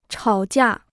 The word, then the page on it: 吵架: chǎo jià